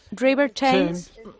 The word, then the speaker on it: River Thames: ¿River Thames? – River Thames.